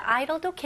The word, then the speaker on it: idle: 네, 공회전은 idol입니다. idol 할 때 idol 발음 똑같아요. 스펠링은 다르지만 idle.